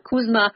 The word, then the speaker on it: CUSMA: Like K-O-O-C? – No. So instead of USMCA, it's C-U-S-M-A. CUSMA. And in Mexico, apparently, it's MUSA. It's M-U-S-C-A.